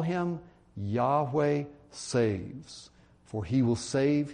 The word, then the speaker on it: Yahweh: Yahweh or Jehovah, some say Jehovah. I think Yahweh is the correct pronunciation of that Old Testament name for God.